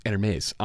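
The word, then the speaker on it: Hermès: And same thing is true with this Hermes envelope. Was that good? Good pronunciation? – That was pretty good.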